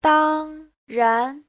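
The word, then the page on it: dangran: dang1ran2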